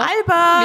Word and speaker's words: Elbert: Albert! Mir ist übrigens wichtig, dass ich das so ausspreche.